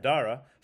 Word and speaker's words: Adara: The last year's winner, I believe she's back, and that's Adara. Yeah. Am I saying that right? – Something like Adara.